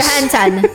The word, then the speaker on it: Scarlett Johansson: Johansson. – Ah, vamos tomar no cu. Deixa eu ler. É um jornalismo sério aqui, cara. Tô tentando ler a manchete aqui. Scarlett Johansson processa Disney por lançamento de Viúva Negra no...